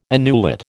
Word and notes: annulet: American English

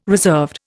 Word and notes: reserved: Indian

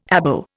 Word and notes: Abo: Indian

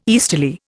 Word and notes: easterly: Indian

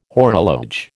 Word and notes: horologe: American English

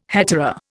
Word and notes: hetaera: Indian

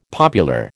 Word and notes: popular: American English

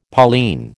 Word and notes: Pauline: American English

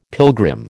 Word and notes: pilgrim: American English

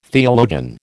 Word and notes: theologian: American English

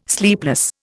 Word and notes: sleepless: Indian

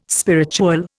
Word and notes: spiritual: Indian

